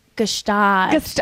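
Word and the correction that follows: Gstaad: Well, in French we say Gstad. – Okay, say it in French. – Yeah, that was really hard. – Gstad. – So there's a G.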